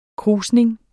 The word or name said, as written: krusning